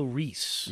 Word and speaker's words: Ruiz: Angelo Ruiz. Or no, sorry, Angelo Reese. – Yeah, it's kind Reese. It's pronounced Reese. – Yeah, it's spelled Ruiz,